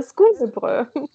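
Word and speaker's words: skolebrød: Was hast du uns denn heute mitgebracht und was ist Skolebrot? Habe ich das richtig ausgesprochen? Fast richtig, äh, Skolebröt. Skolebröt? Ja, genau.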